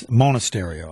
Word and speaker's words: Monasterio: Actually, I did not have it right. It's Monasterio. I think that's what Sophia said, right? Isn't that what you said? How did you pronounce it? – Yeah.